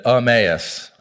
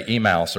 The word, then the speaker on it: Emmaus: I actually had a neighbor walking by my house yesterday and said, "How do you pronounce that?" I said, "Emmaus." I said, "You know, so many people say Emmaus or Emos." He goes, "Yeah, I was saying Emos."